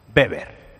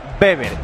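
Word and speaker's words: Weber: Pero el líder en confort térmico de tu vivienda tiene un nombre y se llama Beber. – Beber. Beber. – Aunque lo veáis escrito por ahí con W, que es como se escribe, se pronuncia Beber.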